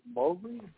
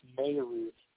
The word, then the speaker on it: Moehrig: I think the social media team made a big deal about how we should pronounce his name, because everybody would pronounce it Moehrig, but they told everybody that it's Moehrig.